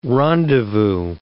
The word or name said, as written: rendezvous